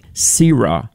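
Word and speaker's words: Sirah (צִרְעָה): There's a Hebrew word, T-S-I-R-A-H, and I don't know, I think it's actually pronounced like Sirah. The T is silent, I think.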